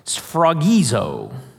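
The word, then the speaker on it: sphragizo (σφραγίζω): Now, in Greek, this word seal is pronounced sphragizo. Sphragizo.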